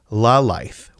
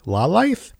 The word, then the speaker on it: Lalaith: I believe that's the pronunciation. L-A-L-A-I-T-H. Laleith. Laleith, I think is it.